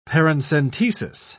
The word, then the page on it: paracentesis: British English